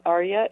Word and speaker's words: Arya: Just Singing in the Dark, and my name, Arya, is spelled A-R-Y-A-F Jenkins.